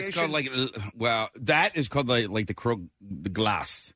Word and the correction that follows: glace: Glace. – Something like that. – How do you say it, Frenchman? – Glace. – Glace.